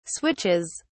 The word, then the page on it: switches: British English